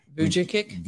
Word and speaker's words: Vujicic: Vujicic. – Nick Vujicic.